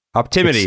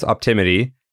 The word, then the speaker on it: Optimity: it's okay, so it's it's pronounced optimity. It's spelled O P T I M I T Y.